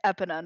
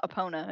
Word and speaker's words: Epona: I was told I was completely fucking wrong, and it's Epona, not Epona.